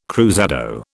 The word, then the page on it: cruzado: British English